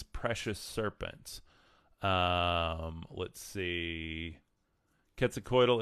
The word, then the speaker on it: Quetzalcoatl: Some people have aligned him with like uh, Quetzalcoatl, uh, and Quetzalcoatl is um, it's uh, Spanish pronounced Quetzalcoatl.